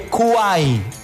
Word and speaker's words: Kwai: Se escribe, se dice Kuai, pero se escribe K-W-A-I latina. K-W-A-I latina.